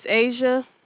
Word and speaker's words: Asia: My name is Asia. – Can you spell that? – Like the continent Asia, A-S-I-A.